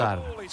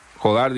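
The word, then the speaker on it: Jódar: Jodar. – Bueno, Jodar dice acá. Jodar es el apellido. – Pero ¿no ves el acento en la O que ponen en tu...? – Ok, no, ahí no tenía.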